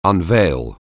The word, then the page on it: unveil: anglais